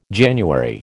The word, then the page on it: January: American English